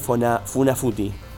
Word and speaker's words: Funafuti: también está el atolón de Funafuti, ¿no? ¿Se dice así? – Sí.